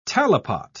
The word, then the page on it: talipot: British English